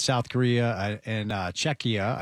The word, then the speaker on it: Czechia: Then it's South Korea and Czechia, I believe that's how you say it.